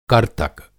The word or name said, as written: कर्तक